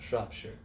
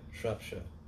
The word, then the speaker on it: Shropshire: He looked up at me, and he's got deep blue eyes, and he just pierced me, and he went, Shropshire. And I was like, yes, sir, Shropshire it is, you know, because that's the proper pronunciation of it in England because there's actually Shropshire, England.